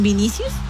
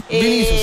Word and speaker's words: Vinicius: De hecho, ahora está con Vinicius. No lo dije bien. – Vinicius se llama.